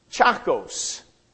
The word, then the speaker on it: Tchacos: Chakos. And it has been, if you look at the word, it's spelled T-C-H-A-C-O-S.